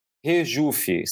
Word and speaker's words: Regufe: E o empresário que você mencionou é o Ricardo Rejufe. Se, é, se, se escreve com G, Regufe, mas se fala Rejufe